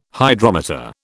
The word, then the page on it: Hydrometer: British English